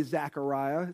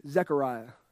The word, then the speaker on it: Zechariah: Um, but in chapter 12, Zechariah chapter 12, and don't say Zechariah, it's not Zechariah, it's Z E, Zechariah.